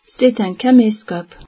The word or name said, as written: caméscope